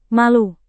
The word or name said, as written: malu